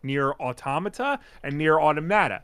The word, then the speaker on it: Automata: What, autom- uh, well, so I always thought it was Automata. – So did I. – But in in the trailer, they pronounce it as Automata.